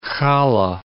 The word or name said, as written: challa